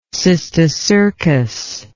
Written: cysticercus

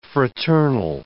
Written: fraternal

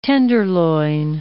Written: tenderloin

